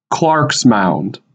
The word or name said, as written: Clarks Mound